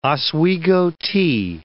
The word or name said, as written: Oswego tea